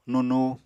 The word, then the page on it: nunu: Apatani